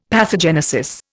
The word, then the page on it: pathogenesis: Indian